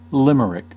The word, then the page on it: limerick: American English; /ˈlɪmərɪk/